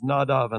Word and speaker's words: Nadav: And now Aaron's sons, and I'll adopt here the Hebrew pronunciation of their names, Nadav and Abihu, each took his censer, put fire in it, laid incense on it, and offered alien fire.